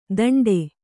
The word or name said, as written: ದಂಡೆ